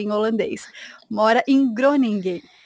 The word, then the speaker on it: Groningen: Pois é, em holandês o G tem som de R. Então a cidade se chama Groningen. – E daí Nossa, é o segundo, é o segundo G depois do N não se pronuncia. Fica tipo nha, Groningen, Groningen.